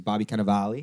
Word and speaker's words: Bobby Cannavale: Our feature interview today is Bobby Cannavale. Am I saying that right? – You said it really good.